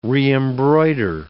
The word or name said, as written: reembroider